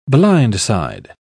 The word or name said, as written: blindside